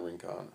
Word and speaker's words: Rincon: Was it Rincon or Rincon? – Rincon. I always pronounce it Rincon. – Rincon. Yeah.